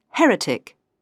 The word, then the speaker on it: heretic: The stress is on the last but one syllable for the words with an ick ending, except Catholic and heretic.